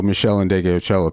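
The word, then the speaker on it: Meshell Ndegeocello: Michelle and Indigocello, one after another, there you were. – You said it right. Good. – Is that the order? – No, Michelle and Indigocello.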